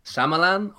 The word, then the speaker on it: Shyamalan: How do you pronounce it? – Shyamalan? Okay. Shyamalan. Shyamalan Ding Dong.